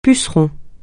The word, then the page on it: puceron: francais